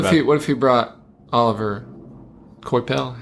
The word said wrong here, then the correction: Olivier: I think it's also Olivier, because there's two I's in it.